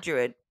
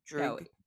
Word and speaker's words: Druig: It's not Druid, but it's Druig. – No. D-R-U-I-G. – It's like Druig. – Druig. Anyway. – Druig.